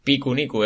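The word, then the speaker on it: Pikuniku: Picuniku es como picnic, eh, pero con la pronunciación japonesa de, picnic, Y. con P, ¿no? Sí. Con, con P y con K.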